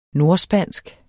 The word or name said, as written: nordspansk